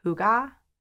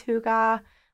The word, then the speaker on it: hygge: So they have this term, and I don't know if I'm pronouncing it correct, but I think it's pronounced hygge.